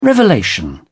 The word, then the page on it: revelation: British English